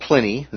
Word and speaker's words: Pliny: There's a fellow named Pliny, the name rhymes with Winnie, okay, wrote that bronze made in this region was renowned for its very fine coloring.